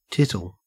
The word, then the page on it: tittle: Australian English